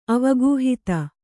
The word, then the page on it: ಅವಗಾಹಿತ: avagāhita